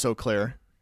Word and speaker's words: Eau Claire: Yeah, that's how you pronounce Eau Claire.